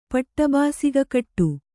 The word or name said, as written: ಪಟ್ಟಬಾಸಿಗಕಟ್ಟು